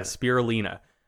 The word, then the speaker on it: spirulina: So, we're talking about, we looked up the pronunciation before this started, spirulina, Lina. Right? Yeah, spirulina.